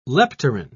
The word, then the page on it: leptorrhine: British English